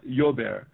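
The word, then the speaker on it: llover: Actually, the last word is pronounced like as though it began with a Y or a J. It's a Jober or Jober. – Jober. – Depending where you come from. Yeah, the double L is like that.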